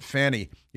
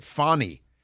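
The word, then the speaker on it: Fani: which is why you can't pronounce it Fanny. You have to pronounce it Fawny.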